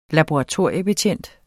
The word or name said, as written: laboratoriebetjent